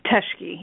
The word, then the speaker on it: Teschke: How do you say your last name, Teschke? – Teschke, yeah, that's correct.